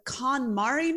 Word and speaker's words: KonMari: And you're saying the KonMari method? Am I saying that right? – Yes, that's correct.